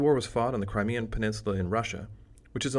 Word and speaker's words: Crimean: It is pronounced Crimean, like Crimea River, and given its history, that may be appropriate.